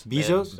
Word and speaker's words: Bezos: So first off, Jeff Bezos, I don't know how to say his name. – Bezos. – That's what I said, dude. – Cuban part, no? – That's exactly what I said. – Bezos. – Like, I'm going to give you a beso.